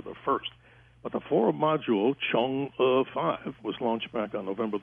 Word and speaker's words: Chang'e: You know, George, if you look around the internet and check, so many people are getting that wrong. And I tried to prepare for the show to make sure I tried to pronounce it right as Chang'e 5.